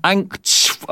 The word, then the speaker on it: Angstschweiß: The German word Angstschweiß, anxiety sweat, has 8 consonants in a row, which is the most in that language. That's even for Germans, that's a lot of consonants. Angstschweiß.